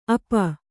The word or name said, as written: ಅಪ